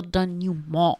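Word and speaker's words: dénouement: The denouement. – In my mind, whereas... – Denouement. – Yes. – I don't think you pronounce the T because it's French.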